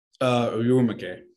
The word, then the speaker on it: Urumqi: Urumqi.